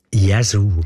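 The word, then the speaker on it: Yazoo: es richtig aussprechen oder nicht? Jesu. – Jesu. – Gell? Jesu. – Ja. – Richtig.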